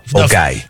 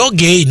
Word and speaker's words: Ogay: O guy. – Não, viraria o gay, não? – É, escrito fica O-G-A-Y. – É, então como que pronuncia o? – O guy, como o... duas sílabas.